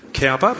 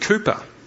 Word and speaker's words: Cowper: You might say Cowper, but his name's actually pronounced Cooper.